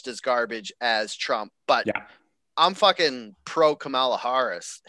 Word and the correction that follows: Kamala: Why did I say that wrong? Anyways, Yeah, a bunch of like hard A sounds there. So like Kamala Harris.